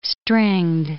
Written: stringed

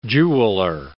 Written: jeweller

